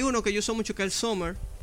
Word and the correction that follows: Summoner: El Summer, ¿cómo es? Summer, ¿cómo es? es? Summoner, el invocador.